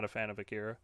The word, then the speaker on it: Akira: And I don't know how to say it, but I guess it's Erica. I would say Erica, but I'm pretty sure it's pronounced some. – Akira? – That's number one. Akira, yeah, that's number one on this list.